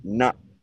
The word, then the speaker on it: natto: That's, you pronounce it natto. Though you gotta stop your throat, not like that.